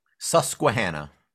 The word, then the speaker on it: Susquehanna: Is it I always find it interesting because is it Susquehanna County? Is that how you pronounce it? – Susquehanna.